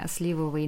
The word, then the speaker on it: сливовый: Еще вопрос, э, почему сливовый на и ударение? Потому что слива?